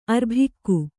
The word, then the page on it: ಅರ್ಭಿಕ್ಕು: kannada